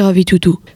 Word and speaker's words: ravitoto: Le premier plat que nous vous présentons s'appelle le ravitoto, prononcé ravitoto.